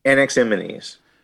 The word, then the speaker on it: Anaximenes: So then we get to, how do you pronounce Anaximenes? – Anaximenes.